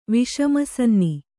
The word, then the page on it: ವಿಷಮಸನ್ನಿ: kannada